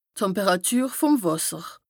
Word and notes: d' Temperàtür vùm Wàsser: Bas Rhin; Strasbourg

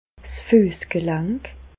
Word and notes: 's Füessgelank: Bas Rhin; Reichshoffen

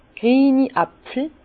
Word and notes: grieni Äpfel: Bas Rhin; Herrlisheim

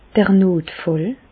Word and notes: d'r Notfàll: Bas-Rhin; Reichshoffen

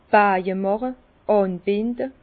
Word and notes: 's Baje màche: Bas Rhin; Herrlisheim

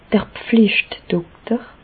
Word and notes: d'r Pflicht-Dokter: Bas Rhin; Reichshoffen

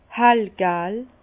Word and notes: hellgal: Bas-Rhin; Herrlisheim

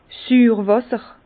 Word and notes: Süürwàsser: Bas Rhin; Herrlisheim

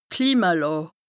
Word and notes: d' Klimalàà: Bas-Rhin; Strasbourg

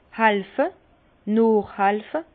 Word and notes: hàlfe, noochhàlfe: Alsatian; Bas-Rhin; Herrlisheim

